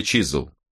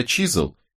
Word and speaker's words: Чизуэл: Моя фамилия Чизл». – «Как пишется?» — спросил Робин, чтобы не ошибиться. – «Чи-зу-эл. Джаспер Чизл.